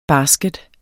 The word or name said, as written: basket